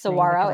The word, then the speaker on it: Saguaro: Saguaro, it's okay.